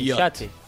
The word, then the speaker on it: yacht: Que se escribe Jacket. Yacht. Y se dice yacht.